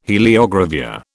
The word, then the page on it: heliogravure: British English